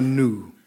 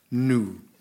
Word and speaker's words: gnu: Gnu isn't pronounced gnu. It's pronounced nu. – What? – Yes. – No. – Yeah. – No, it's gnu. – No, nu. – But I've always said gnu. – You've always been wrong.